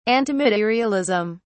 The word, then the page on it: antimaterialism: British English